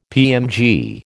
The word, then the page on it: PMG: American English